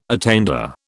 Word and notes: attainder: British English

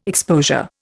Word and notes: exposure: Indian

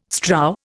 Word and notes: strow: Indian

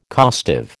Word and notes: costive: American English

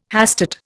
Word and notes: hastate: Indian

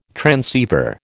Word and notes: transceiver: American English

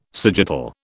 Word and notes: sagittal: British English

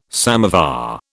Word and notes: samovar: British English

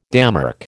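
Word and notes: démarche: American English

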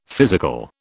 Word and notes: physical: British English

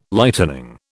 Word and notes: Lightening: British English

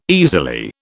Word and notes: easily: British English

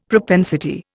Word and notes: propensity: Indian

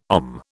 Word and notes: OM: British English

